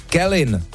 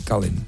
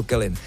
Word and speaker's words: Kelin: Jediná správná výslovnost je, přátelé, Kelin. Ne Kalin. Mně tam taky skáče Kalin, chápu vás, ale je to Kelin.